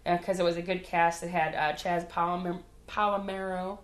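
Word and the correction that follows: Chazz Palminteri: I can't say his name. – I don't know who it is. – Sharon Stone, though. Kathy Bates. – Kathy Bates. – Chaz Palminteri, rather.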